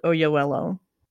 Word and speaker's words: Oyelowo: It's just this time it's George Clooney, Felicity Jones, and David Oyelowo. – Thank you for pronouncing that. You did a very good job.